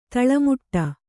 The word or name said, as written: ತಾಳೆ ಮಾಡು